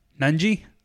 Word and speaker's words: Nunge: Uh, Xavier's got a legit seven footer that could, that could defend this fool, Jack Nunge I think that's how you pronounce oh, Nunji. it. – Nunji.